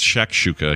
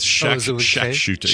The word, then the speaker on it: shakshuka: I'm actually pronouncing it wrong. It's shakshuka. I keep saying shat with a T. It's shakshuka. Yeah, more like Shaq O'Neal, you know, like a basketball great Shaquille O'Neal's shortcut name.